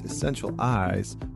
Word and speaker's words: Essentialize: I am Connor Beaton, and joining me today is Lee Chambers, who is an environmental psychologist, well-being consultant, and founder of Essentialize, pronounced Essential Eyes, workplace well-being.